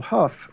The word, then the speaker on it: Hough: Hough, which is H-O-U-G-H, but it's pronounced Hough.